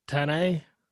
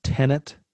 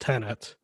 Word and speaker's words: Tenet: Even the name, if I mention why for certain it's pronounced Tenet. – Well, they said it in the trailer. That's how I know that it's pronounced Tenet.